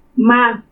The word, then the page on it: Ma: Purépecha